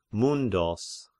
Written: Myndos

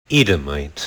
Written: Edomite

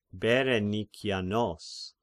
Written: Berenikianos

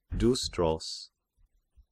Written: Dystros

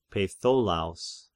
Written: Peitholaos

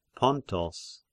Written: Pontos

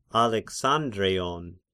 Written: Alexandreion

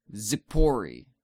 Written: Tzippori

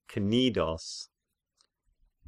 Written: Knidos

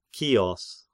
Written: Chios

